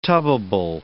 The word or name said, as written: tubbable